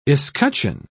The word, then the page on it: escutcheon: British English